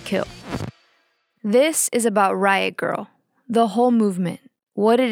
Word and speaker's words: Grrrl: The movement came to be called Riot Girl. That's girl spelled with no I and three R's. It's meant to be pronounced with a sort of growl.